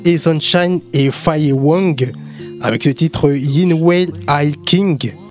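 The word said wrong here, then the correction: Ai Qing (爱情): Est-ce que je le prononce bien? – Oui, presque. Yin Wei Ai Qing.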